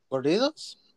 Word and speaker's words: corridos: Is it Corrido? Is that how you say it? – Corridos? – Yeah.